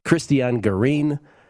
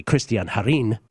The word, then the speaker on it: Cristian Garin: He had Cristian Garin. That's actually Cristian Garin, if you want to pronounce it correctly, from Chile, against Felix Auger-Aliassime.